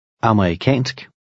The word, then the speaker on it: amerikansk: In number 4, the stress is on the last syllable, and the E is very slight. Try it. – Amerikansk.